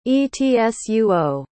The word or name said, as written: etsuo